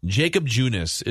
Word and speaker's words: Junis: And is it pronounced Jacob Junis? – Sure. – Jacob Junis is the starting pitcher.